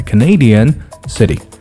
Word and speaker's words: Canadian: 注意 Canadian 重音呢发生了变化啊，不是在读作了 Canadian, okay, Canadian city.